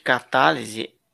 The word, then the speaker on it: Ktalise: Lembrando, lembrando que Catálise escreve, se escreve K-T-A-L-I-S-E, correto? – Exatamente, é, é um K mudo, K-T-A-L-I-S-E.